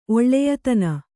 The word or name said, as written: ಒಳ್ಳೆಯತನ